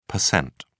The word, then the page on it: pct: British English